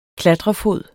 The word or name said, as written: klatrefod